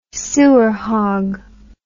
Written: sewer hog

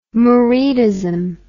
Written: muridism

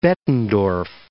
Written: Bettendorf

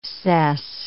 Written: cess

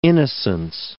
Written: innocence

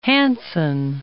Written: Hansson

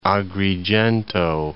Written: Agrigento

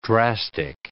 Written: drastic

